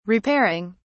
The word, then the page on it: repairing: British English